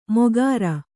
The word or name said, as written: ಮೊಕರ